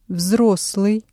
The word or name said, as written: взрослый